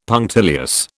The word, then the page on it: punctilious: British English